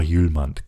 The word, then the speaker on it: Hjulmand: Also er schreibt sich ja H-J-U-L-M-A-N-D, also H-Julmand habe ich ihn zuerst gelesen, aber heißt einfach Julmand, Kasper Julmand.